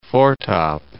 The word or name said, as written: foretop